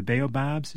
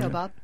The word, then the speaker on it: Baobab: That's K-O-T-O-R, if I'm mispronouncing it. Number three, Avenue of the Baobabs. Is that how you pronounce that? – Yeah, sort Baobab. Mm-hmm. – Baobab, okay.